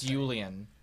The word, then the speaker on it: Yulian: It's Yulian, by the way. – Yulian. – Y-U-L-I-N. – Yeah. – So like Yosef, but Yulian.